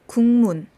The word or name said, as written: gukmun